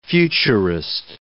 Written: futurist

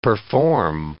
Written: perform

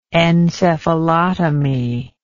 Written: encephalotomy

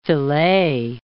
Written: filet

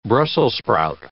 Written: brussels sprout

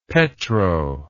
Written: petro

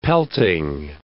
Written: pelting

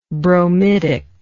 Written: bromidically

